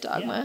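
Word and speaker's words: Dogme: Is that how you say it? Dogma? Yeah. – But it's not spelt with an A, it's spelt with an E, which is stupid.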